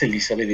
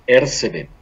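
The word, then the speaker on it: Erzsébet: Elizabeth Bathory, que realmente el nombre correcto no es Elizabeth, es Ercebeth.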